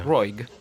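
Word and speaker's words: Roeg: Come si eh, pronuncia? Me lo chiedo da una vita. – Roig. Roig.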